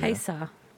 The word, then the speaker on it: Haysi: Okay, H-A-Y-S-I. Just how would you pronounce that? That is a town in Virginia. – Haysa. – Correct, yes.